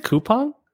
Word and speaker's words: Coupang: Will you buy Navios, the one he pitched at the end of our last episode, or Kuaping? – Or Coupang. – Is it Coupang? – That's how I was told it was pronounced.